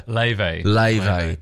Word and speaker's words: Laufey: The pronunciation of the artist, I'm in my head with her. – Laufey. – But she's spelled L-A-U-F-E-Y.